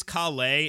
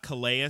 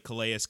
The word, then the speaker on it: Calais: And it is Calais. It's not Calais like Calais Campbell. It's the French pronunciation, whatever.